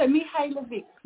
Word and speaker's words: Mihajlovic: That was pretty good. Pretty good, John. So very good. Pretty good. So Mihajlovic. It's a silent J. – Okay. – That the J throws people out.